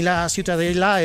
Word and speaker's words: Ciutadella: Para mí la Ciutadella es...